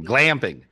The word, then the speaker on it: glamping: And so, but it comes from somewhere, so which proves God hangs out and even goes clamping with me, or glamping. Did I say it right? Clamping? No, not clamping. Glamping, and eat with me, camping, and so.